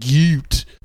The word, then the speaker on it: gyat: You are such a boomer that you don't know what G-Y-A-T means. It's one, you don't just say the letters. You pronounce it gute. – A gute, like... – Sort of a German gute. – Like a... Cute? Is it... – Oh. Gute. It's sort of like deeper.